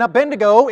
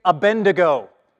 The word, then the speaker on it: Abednego: Because if you grew up in Sunday school, you say Abednego, and you read it like a bend, like bend, you know, bend your elbow.